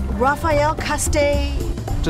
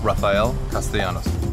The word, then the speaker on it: Rafael Castellanos: Oh, it's Rafael Castellanos. – Just remember, it's the longest name on the ballot, Rafael Castellanos.